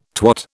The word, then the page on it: twat: British English